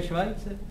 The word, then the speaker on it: Schweitzer: No to se vyslovuje Švajce. – Co? – To je Švajce právě.